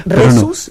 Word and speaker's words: Rhesus: Exacto, es Rhesus, no. ¿Y el H? – Rhesus, RH, así se pone, Rhesus, se escribe RH Esus.